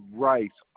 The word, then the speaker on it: Rice: Rice, R-I-C-E.